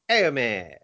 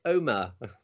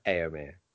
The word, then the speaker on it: Éomer: I've been saying that wrong for a very long time, but Carly told me it's pronounced Éomer. I've been calling it Omer, but no, Éomer.